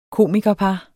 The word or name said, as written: komikerpar